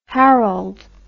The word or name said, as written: harald